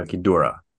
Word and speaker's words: Ghidorah: Yeah, Ghidorah.